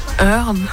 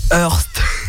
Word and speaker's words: Earth: je sais toujours pas comment le prononcer. – Earth. – Je vais t'aider Minecraft Earth. – D'accord. – C'est de l'anglais, c'est de l'anglais William, attention, c'est pas de l'allemand.